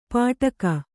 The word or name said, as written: ಪಾಟಕ